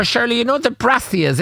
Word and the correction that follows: Brassica: Is that how you pronounce it? The brassias? – Brassica.